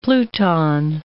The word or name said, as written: pluton